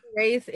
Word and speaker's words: hiraeth: Like the Hiraeth, is that how you say it? Yeah. Hiraeth is,